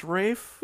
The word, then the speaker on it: Ralph: Isn't his first name Ralph, but pronounced Rafe? – Yes.